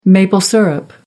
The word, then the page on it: maple syrup: American English